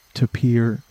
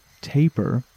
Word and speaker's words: tapir: Some resources say that it can rhyme with paper, meaning that tapir could be pronounced taper, depending on which part of the world you are from, but for this episode, I am going to be saying tapir.